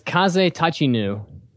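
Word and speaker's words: Kaze Tachinu (風立ちぬ): The movie's name is Kaze Tachinu, which in Japanese, the translation is The Wind Rises.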